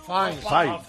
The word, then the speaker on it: Fiennes: Se escribe Fiennes. – Fiennes solo. – Fine.